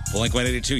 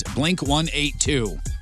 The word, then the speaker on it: Blink 182: The British people pronounce it Blink 182. – Do they really? – Yeah. Some comedian mentioned it in a Twitter, and it's gone pretty viral here. Some Brits are defending how they say it and the proper English, of course. They said, quote, if it's a number, it's 182. If it's figures, then it's 182.